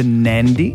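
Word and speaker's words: Nadi: Flights out of the Gold Coast to Nandi. – Yes. – Did I say that right? – Yeah. – I said it right, yeah.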